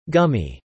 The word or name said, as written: gummy